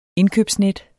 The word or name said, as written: indkøbsnet